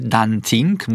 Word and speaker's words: dancing: Natomiast wymowa może być zawsze tylko przez sy, czyli na dancingu byłem i na dancingu się bawiłem i na dancing się wybieram.